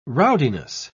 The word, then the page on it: rowdiness: British English